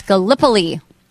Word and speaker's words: Gallipoli: No, it's Gallipoli. FYI. Just fun fact. – You know, I could have gone either way. Gallipoli. Let me see. Gallipoli, yeah. – Gallipoli, but you have some really funny, really heartfelt messages.